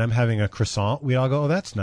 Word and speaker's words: croissant: They say you're supposed to pronounce it K-R-W-A-H. – Croissant. – Dash S-A-H-N. Croissant.